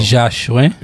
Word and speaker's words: Joachim: You want to Haitian file your last name or you want to keep it real American for me? Joachim. – Joachim. – That's how you say it in Creole? – Joachim.